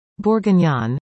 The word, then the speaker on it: bourguignon: Bourguignon, pronounced bourguignon, means of Bourgogne, Burgundy in English, an east-central region of France renowned for its wine.